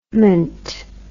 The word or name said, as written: ment